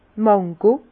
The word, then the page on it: d' Mango: Bas Rhin; Herrlisheim